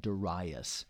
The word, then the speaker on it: Darius: In the 8th month, in the 2nd year of Darius. Some people pronounce that Darius.